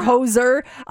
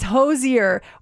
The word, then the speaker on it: Hozier: Is it Hozier? – Hozier. Yes. – Okay. – When he first came out, I called him Hozier. – Yeah. – Or Hozer. It's Hozier.